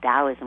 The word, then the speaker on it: Taoism: Yeah, I mean, the T is basically pronounced like a D in Chinese. It would be called Taoism, would be the proper pronunciation, but it's spelled T-A-O, or you can find it spelled D-A-O, but it's the same thing.